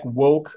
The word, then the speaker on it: Woche: I guess that one of the bit of a surprise, Jack Woche, I think that's the way he pronounces his name, W-O-C-H-E.